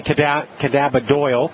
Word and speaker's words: cannabidiol: Yeah, cannabidiol. Well, some people say cannabidiol, mm-hmm. depending how you want to pronounce it.